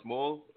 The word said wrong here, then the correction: Smule: a Smole or Smole? – Yeah, Smule, yeah. – Smule, yeah, mm-hmm.